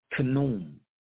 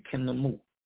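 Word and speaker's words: Khnmu: And so in Kemet, they preserved this knowledge in another name for God, who would be pronounced something like Kenum or Kenal or the Kenamu.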